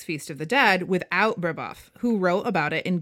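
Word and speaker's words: Brébeuf: Brebeuf.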